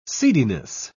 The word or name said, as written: seediness